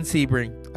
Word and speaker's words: Sebring: But first, Steven Sebring. I've been saying it right.